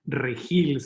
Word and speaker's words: Regil: Well, first of all, thank you, and no worries about butchering my last name, which is a hard name to pronounce in English. It's Regil, so people go Regil.